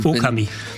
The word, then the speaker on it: Okami: Okami. Betonung auf dem O.